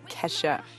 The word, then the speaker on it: Kesha: It's Kesha. And yes, that is spelled K to the E to the dollar sign to the H to the A.